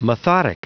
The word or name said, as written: methodic